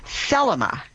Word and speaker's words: Thelema: Well, we got a follow-up email, and what she was asking about is something called Thelema. T-H-E-L-E-M-A was the emphasis on the first syllable.